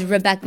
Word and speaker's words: Rebecca: Actually, my name is Rebecca.